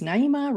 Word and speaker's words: Naima: So my name is Naima Sheikh. I always say that it's Naima rhymes with arena or hyena. Pick one depending on the mood, right?